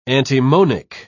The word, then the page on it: antimonic: British English